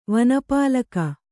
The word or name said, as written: ವನಪಾಲಿಕೆ